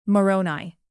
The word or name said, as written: moroni